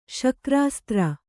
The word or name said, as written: ಶಕ್ರಾಸ್ತ್ರ